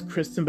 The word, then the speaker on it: Christin: even though it's Kristen, but it's spelled C-H-R-I-S-T-I-N, but most of the time people misspell it,